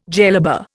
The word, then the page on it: jellaba: Indian